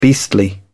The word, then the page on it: beastly: British English